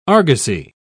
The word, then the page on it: argosy: British English